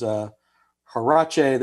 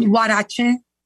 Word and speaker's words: huarache: And they sent me a couple of dishes to try, one of which was huarache that was super crispy, very delicious. – And it's called huarache, but that's okay.